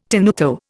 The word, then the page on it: tenuto: Indian